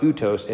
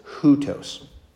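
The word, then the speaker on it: hutos (οὕτως): So that's where you would add the H sound. So it's not utos, it's hutos.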